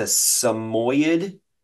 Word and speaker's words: Samoyed: It's a Samoyed.